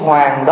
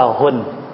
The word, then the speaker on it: Huỳnh: cái chữ Hoàng đó phạm húy, cho nên mới đọc là Huỳnh.